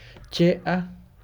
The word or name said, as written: a